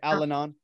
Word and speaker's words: Al-Anon: I went to therapy instead of Al-Anon. I looked into it. How do I say it? – Yeah, that's right. Al-Anon.